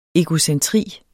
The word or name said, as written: egocentri